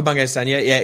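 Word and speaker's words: Bungay Stanier: You look like you've talked to or read a lot about Michael Bengay-Stanya. Is that how you say his name? – Yeah. Michael Bengay-Stanya, is a great example when it comes to coaching and a phenomenal thought leader in that space.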